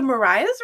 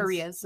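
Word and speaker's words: The Marías: Is it the Mariahs or the Marias? Because I've heard it pronounced... – Marias. – The Marias. That's what I thought. – Marias. Like... – That's what I thought, but someone was like, it's the Mariahs. I was like, are you sure? – Mariah's got a fucking H on the end.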